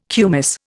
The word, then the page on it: kumis: Indian